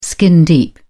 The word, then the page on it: skin deep: British English